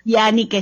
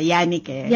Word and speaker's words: Janique: Actually, gusto ko lang sabihin yung pag-pronounce ng pangalan ko. – Ano? – Yannike siya, Yannike.